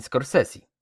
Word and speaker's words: Scorsese: A poza tym reżyserem jest Martin Scorsese. Tak, tak się wymawia to nazwisko, przed chwilą sprawdziłem. Głównego bohatera gra Willem Dafoe, a założyłem, że głównym bohaterem jest Jezus Chrystus.